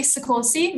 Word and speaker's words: Soccorsy: My name is Ellie Soccorsy.